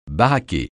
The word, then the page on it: baraqué: francais